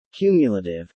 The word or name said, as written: Cumulative